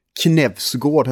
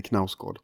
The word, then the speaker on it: Knausgård: Jag kommer att tänka på um, den sjätte och sista volymen i eh, Knevsgård har jag förstått att man ska uttala, inte Knausgård.